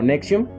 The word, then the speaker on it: NXIVM: Esto de NXIVM, bueno, realmente ellos lo pronunciaban como Nexium.